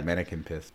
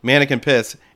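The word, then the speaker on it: Manneken Pis: Uh, from Brussels with uh, man piss or what mannequin piss? How do you say it? What is yeah, it? Yeah, mannequin piss. – Mannequin piss, and the corkscrew is the pee coming out of the thing.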